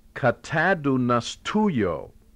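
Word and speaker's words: katadunastuyo (καταδυναστεύω): And it's pronounced katadunastuyo. How's that for a mouthful? KATADUNASTEUO.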